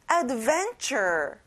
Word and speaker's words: adventure: 모험, adventure. 여기 끝에 er 발음을 잘해주고요. van에 강세를 넣어보는 거예요. Adventure. Very good.